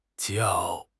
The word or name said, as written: jiao4